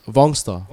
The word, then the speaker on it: Vongsta: Vongsta. Say again? – Vongsta. – Vongsta. – Can you spell that? – V-O-N-G-S-T-A.